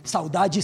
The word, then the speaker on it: saudade: Saudade, saudade, es un término portugués que así se pronuncia en el archipiélago.